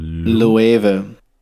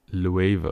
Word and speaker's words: Loewe: Is it Le or Loewe? – Loewe, okay. This is the latest collab with Loewe. I didn't even know it was pronounced like that.